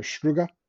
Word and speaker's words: Struga: Bunch of artwork found behind a 16th century wall paintings hidden behind plaster walls while doing renovation work in an old palace near Struga. Did I say that correctly? The village of Struga? – Yeah, that sounds right.